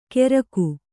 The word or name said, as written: ಕೆರಕು